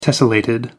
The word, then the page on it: tessellated: American English